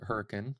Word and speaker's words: Hurricane: Grew up in a little town called Hurricane, or how we like to pronounce it, Hurricane.